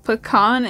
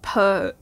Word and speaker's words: pecan: Pecan is pronounced pecan, not pecan. – I can't argue with that because I say both.